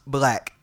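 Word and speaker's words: 6lack: But, man, I got to start with this nigga Black, or for y'all niggas, Six Lack. Pronounced Black.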